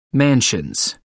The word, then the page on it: mansions: American English